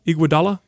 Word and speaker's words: Iguodala: Iguodala? – Yes.